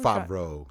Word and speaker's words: Favreau: But there's Vince Vaughn and I can't pronounce his last name. Favreau. – Favreau, yes.